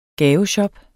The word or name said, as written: gaveshop